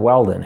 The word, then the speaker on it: Weldon: However, I was going through the army and just throughout life and everything, everybody thought Weldon, my last name, was too complicated, too hard to pronounce. So they started saying well done.